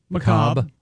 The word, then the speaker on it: macabre: Macabre. Yeah, the R-E silent or whatever.